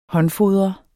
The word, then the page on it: håndfodre: [ -ˌfoðʁʌ ]; [ -ˌfoðˀʁʌ ]